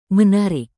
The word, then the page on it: menarik: indonesian